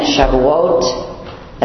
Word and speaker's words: Shavuot: It's the last week of the study, and we're looking at Shavuot. That's how you pronounce that Hebrew word, which is Pentecost to us.